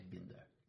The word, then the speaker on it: Binder: The name should be pronounced by the rules of German. So okay. – It would be a short I, Binder.